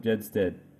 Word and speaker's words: Gjedsted: And I'm here today with Mr. Philip Gjedsted from Bali. Phil, how are you? – I'm good, thanks. And that's great. You got the name right. – It is Gjedsted, isn't it? – It's very good.